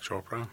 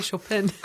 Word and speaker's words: Chopra: How do you say it? – Deepak Chopra. – Chopra. Thank you. – Yes. – I wanted to say Chopin.